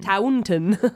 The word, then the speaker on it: Taunton: So he was in that taunting place or whatever. – Yeah. Taunton, I think, is how it's Taunton? – Pronounced. T-A-U-N-T-O-N.